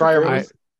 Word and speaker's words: Freiermuth: Were Freiermuth this is last? Yeah. How you pronounce it? – Freiermuth. – Yeah.